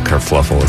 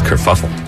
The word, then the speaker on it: kerfuffle: By the way, I've been pronouncing that it's not kerfluffle, it's kerfuffle.